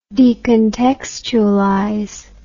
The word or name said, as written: decontextualization